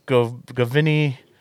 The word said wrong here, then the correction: Giovanni Ribisi: Giovanni Ribisi. – There you go.